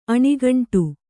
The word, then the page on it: ಅಣುಗುಂಡು: aṇuguṇḍu